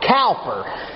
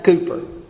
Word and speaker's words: Cowper: His last name looks like Cowper, but I have been told that the English actually pronounce it Cooper.